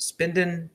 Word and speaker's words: SPEDN: It's spelled S-P-E-D-N, but pronounced Spendin, trying to, I don't know, maybe make a market off the meme of HODL with another misspelled word.